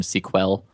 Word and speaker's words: Sequel: So I call the, the ORM SQL. Right. – Spelled out like the movie sequel. – Yes, exactly. Spelled like the mo- like like the word sequel, yeah.